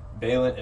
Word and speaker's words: Vālent: Not saying Vālent, actually saying Vālent, how it's supposed to be pronounced. So for y'all that don't know, that keep saying his name wrong, there's a reason why the A's like that. – Yeah, so put some respect on his name. – The A's like that because it's Vālent is like taken out of covalent bonds because of the way I came up with my name.